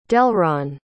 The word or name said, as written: delron